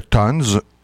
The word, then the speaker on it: Tons: Donc, il s'appelle le Tons ou, en français québécois, le Ton. T-O-N-S. Alors, on le prononce comme on veut.